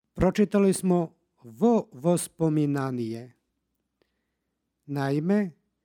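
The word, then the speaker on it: vo vospominanije (во воспоминаніе): između predloga i reči koja počinje istim suglasnikom dodaje se takozvano izgovorno o. Dakle, vo vospominanije.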